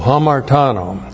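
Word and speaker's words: hamartano (ἁμαρτάνω): Okay, hamartano. H-A-M-A-R-T-A-N, long O, hamartano.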